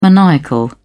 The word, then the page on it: maniacal: British English